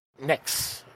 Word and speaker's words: Nyx: And our guest is Kate Nix. Am I pronouncing that part right? – Yes. – All right. – Just like the goddess, the makeup company.